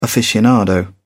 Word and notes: aficionado: English; British English

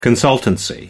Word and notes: consultancy: American English

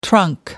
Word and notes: trunk: American English

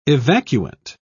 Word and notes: evacuant: British English

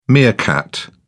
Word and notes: meerkat: British English

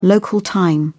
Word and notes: local time: British English

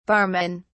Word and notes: barmen: British English

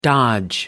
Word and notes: dodge: American English

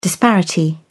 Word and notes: disparity: British English

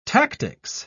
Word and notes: tactics: British English

